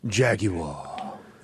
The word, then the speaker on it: Jaguar: Say it right. It's Jaguar. You got to add that extra like Jaguar. Stupid syllable in the Jaguar. – Middle to make it sound Jaguar.